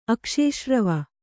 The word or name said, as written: ಅಕ್ಷೇಶ್ರವ